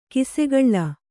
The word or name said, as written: ಕಿಸುಗುಳಿ